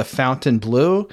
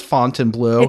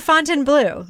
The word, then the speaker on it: Fontainebleau: by calling it the fountain blue no did they just not know how to pronounce it because then somebody else comes on and says fontainebleau it's the fontainebleau it's